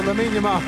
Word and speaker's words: Lamine Yamal: If I said Lamine Yamal, would that sound right to you? – Yeah, that sounds right. – There we go. Lamine Yamal. That's correct.